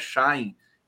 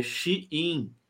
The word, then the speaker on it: Shein: O Jomiag, querido jornalista da TVT, está me chamando a atenção aqui, diz que é, a pronúncia da, da empresa de vendas chinesa não é Shine, é Shein. Shein.